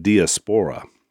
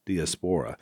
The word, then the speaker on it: diaspora: And so, Dad, you probably used the – I always said diaspora. Diaspora. – Diaspora. – But, yeah, this one says diaspora is the correct pronunciation, but I'm not sure about that.